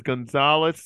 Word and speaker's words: Gonzalzles: It's Armand, A-R-M-A-N-D. Last name is Gonzalez, spelled quite differently, G-O-N-Z-A-L-Z-L-E-S.